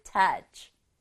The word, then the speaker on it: touch: 우리말로는 터치이지만 영어로는 touch. touch. touch. 한 박자로 발음해요. 터치. 이 되지 않도록 여기 ch의 소리는 ch. ch. 이렇게 바람만 나오는 거예요.